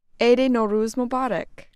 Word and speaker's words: Eid Nowruz Mubarak: Eid Nowruz Mubarak. – Thank you. – You're welcome. Did I say that right? – You did.